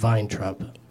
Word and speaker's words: Weintraub: But then you look at your name written as it's spelled, and it's not. It doesn't seem like it would be pronounced Weintraub.